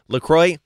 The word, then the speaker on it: LaCroix: It is 100% confirmed LaCroix, not LaCroix. – LaCroix rhymes with joy.